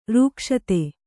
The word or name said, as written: ರಕ್ಷಿತ